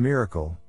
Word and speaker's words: MRKL: We describe this neuro-symbolic architecture, dubbed the modular reasoning, knowledge and language, MRKL, pronounced miracle, system, some of the technical challenges in implementing it, and Jurassic X, AI21 Labs MRKL system implementation.